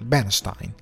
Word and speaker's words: Bernstein: Questo film finalmente è arrivato di Re- Leon, con, eh, che parla diciamo di Leonard Bernstein, eh, anche, anzi è Bernstein, non è Bernstein, è Bernstein nel film lo pronuncia così, come Frankenstein, Bernstein lo pronuncia così.